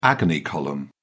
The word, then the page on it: agony column: British English